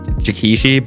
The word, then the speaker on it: Jikishi: No. So I'm going to be honest with you, you're not pronouncing it wrong, but it's okay. Nobody really pronounces my name right on the first try. My name is technically pronounced Jikishi, but people always just call me Jiki for short. – Jiki. – So, yeah, because J-I-K-I, there's like, easy.